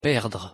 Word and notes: perdre: French

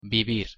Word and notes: vivir: Spanish

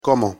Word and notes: como: Spanish

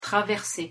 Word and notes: traverser: French

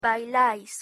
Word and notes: bailais: Spanish

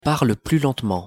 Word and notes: parle plus lentement: French

